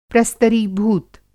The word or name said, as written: प्रस्तरीभूत